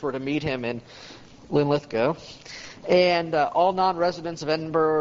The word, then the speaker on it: Linlithgow: How do you say it, Simon? Linlithgow, yeah. I'll try to... thank you. Very handy to have a resident Englishman here to correct my Scots pronunciation. So, yeah, I get the accent's on the second syllable. Linlithgow, okay.